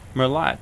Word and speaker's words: Merlot: I don't even know how to pronounce it. Merlot? – Merlot. – Merlot. – M-E-R-L-O-T. Merlot. – Merlot. – Yeah, that's it.